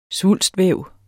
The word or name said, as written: svulstvæv